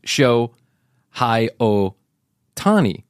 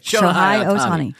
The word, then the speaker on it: Shohei Ohtani: His name is Shohei Ohtani. Okay. – Shohei Ohtani.